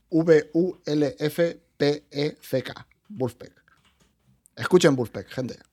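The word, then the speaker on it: Vulfpeck: O sea, tú le pones un disco de Wolfpack,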